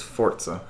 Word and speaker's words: Forza: It is Forza. – I've always called it Forza. There's no T in there. – That's how it's pronounced.